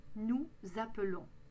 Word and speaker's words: appelons: On prononce un « e » et on ne double pas le « l ». Nous appelons. Vous appelez. Prononcez bien le « a » au début. Appeler.